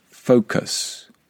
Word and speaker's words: focus: So we say focus, focus. Did you hear the vowel sound at the end? The uh, uh, focus.